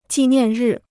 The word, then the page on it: 纪念日: jì niàn rì